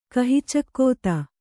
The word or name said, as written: ಕಹಿಚಕ್ಕೋತ